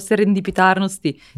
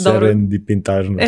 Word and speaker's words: Serendipitarnost: Vidi, da tri života imam ne bi izgovorio odjedno sere... – Serendipitarnost. – Serendipitarnost. – E, blizu si, da. Serendipitarnost.